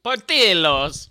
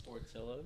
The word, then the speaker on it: Portillo's: Portillo's. – Portillo's.